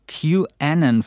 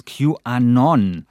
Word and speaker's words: QAnon: Ich habe QAnon falsch ausgesprochen, weil eigentlich heißt es QAnon. Und das ist aber so, so, eine kleine Marotte von mir. Ich sage immer QAnon und das hat dann offensichtlich Alexa für Kreuzkümmel gehalten oder so verstanden.